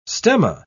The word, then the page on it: stemma: British English